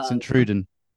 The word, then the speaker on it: Sint-Truiden: Uh, Belgium is a particularly interesting one uh, because uh, Sintrusen, uh, guy, let me know if my mm pronunciation is incorrect. – Uh, Sint-Truiden. Sint-Truiden.